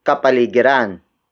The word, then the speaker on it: kapaligiran: It is the focus marker, indicating that the next word is the focus of this sentence, and that is kapaligiran. Kapaligiran. Kapaligiran. Kapaligiran is spelled as K-A-P-A-L-I-G-I-R-A-N.